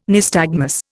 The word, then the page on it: nystagmus: Indian